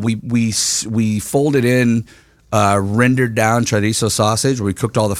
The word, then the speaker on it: chorizo: Chorizo gnocchi. – You said it right.